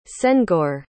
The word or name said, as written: senghor